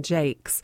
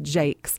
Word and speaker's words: Jacques: His name was Brian Jakes. It's spelled Jacques, but it's pronounced Jakes.